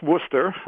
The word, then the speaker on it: Worcester: It's pronounced Worcester.